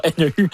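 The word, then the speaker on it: Neu: comment on le prononce, oui. – Je sais pas, NU, exact, c'est comme un grand bol d'air, qui est comme un horizon qui s'ouvre.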